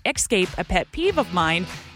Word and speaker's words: Xscape: Actually, the band goes by Xscape. If you look up interviews, it's the letter X, Xscape, and they are called Xscape. That's why I pronounce it that way for that particular group.